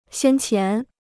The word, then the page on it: 先前: xiān qián